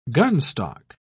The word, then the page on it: gunstock: British English